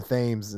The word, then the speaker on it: Thames: No, I think it's the Thames. I think you're saying it wrong, Paige. – I've only read that word, and it is T-H-A-M-E-S, I believe.